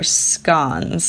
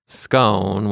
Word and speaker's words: scone: Megan gave them to her aide, saying they were scones, said Linda Clark, 70, noting that Megan pronounced the word with a short O to rhyme with gone. Clark added the correct pronunciation is scone with a long O to rhyme with bone.